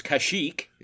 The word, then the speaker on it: Kashyyyk: It's getting a little old, and they take her, and I never knew how to pronounce the Wookiee homeworld. It's Kashyyyk.